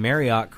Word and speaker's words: Marriott: The Marriott team says that if you're pronouncing Marriott correctly, it should rhyme with chariot. – So Marriott. – Most people seem to pronounce it with a hard ot, like otter, like Marriott. Like what did I say, right? – Mm-hmm.